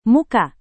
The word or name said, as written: muka